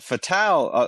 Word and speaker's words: Fatale: It's pronounced Fatale. It's hard. it's hard being the smartest person in most rooms, but that is, in fact, how it's pronounced. I looked it up. Fatale. – No, okay, because I forgot. Yeah, it is a French. It's French origin.